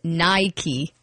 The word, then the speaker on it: Nike: No, so you're supposed to pronounce it Nike. – Nike. – Oh, come Nike. – On. – N-A-I-K-E.